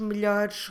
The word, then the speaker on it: melhores: we need to change melhor into melhores, melhores. Como se escreve melhores? M-E-L-H-O-R-E-S.